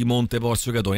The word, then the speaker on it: Monte Porzio Catone: Monte Porzio Catone. Innanzitutto, ho detto bene, Massimo? Tutto giusto? – Sì, hai detto benissimo, tutto giusto.